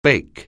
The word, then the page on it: bake: anglais